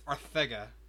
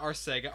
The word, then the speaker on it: Arcega: It came from the man himself. It's Arcega, not Arcega.